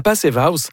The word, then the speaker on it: Passive House: La Passive House, il faut prononcer avec un accent allemand, je vais dire.